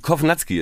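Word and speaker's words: Kownacki: Nein, Kovnatski ist tatsächlich die richtige Aussprache.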